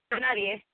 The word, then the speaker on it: Nadie: Is it Piro Nadie? How do I say this? – Oh, yeah. That was perfect. Nadie.